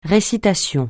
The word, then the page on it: récitation: francais